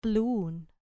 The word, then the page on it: plun: grec